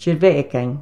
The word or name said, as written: TIERVAAGAIN